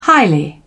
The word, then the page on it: highly: British English